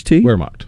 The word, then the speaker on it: Wehrmacht: So the motorcycles, by the way, I'm not sure I could pronounce it. Wehrmacht? Wehrmacht. – Wehrmacht. – Wehrmacht? Okay. Could go either way when you got the Wehrmacht. – Gotcha. – If you want to get real German with it.